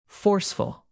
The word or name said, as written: forceful